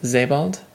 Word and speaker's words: Sebald: Person whose name is actually pronounced Sebald? That last one was definitely on, but I'm going to anglicize it anyways.